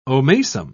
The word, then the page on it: omasum: British English